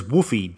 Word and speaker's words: Whuffie: That word is Whuffie, W-H-U-F-F-I-E, pronounced like a dog, woof, woof. According to Wikipedia, Whuffie is the ephemeral reputation-based currency of Cory Doctorow's science fiction novel Down and Out in the Magic Kingdom.